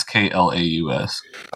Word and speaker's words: Klaus: It's K L A U S. – Okay. I mean, you can say it like Claus if you want to, but it's pronounced Klaus.